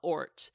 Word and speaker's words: Oort: And her last name is very Dutch as well, Ort, O-O-R-T. And I had a fish. I had a fish named Ort in university. I just want to do a little shout out to Ort.